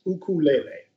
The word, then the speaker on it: ukulele: The correct way to pronounce it in Hawaiian. So it's ukulele.